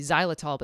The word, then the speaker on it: xylitol: I know it sounds like a Z, xylitol, but xylitol starts with an X.